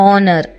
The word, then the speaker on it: honour: HONOUR ಇದನ್ನು ಆನರ್ ಅಂತ ಹೇಳ್ತೀವಿ, ಸನ್ಮಾನ.